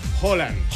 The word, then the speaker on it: Haaland: Holland. – Es con O. – Y es rápido, es Erling Haaland.